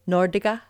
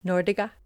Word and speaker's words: Norge: Did you know that Norwegians pronounce Norway Nordiga? – Nordiga. – And it's spelled N-O-R-G-E, Nordiga.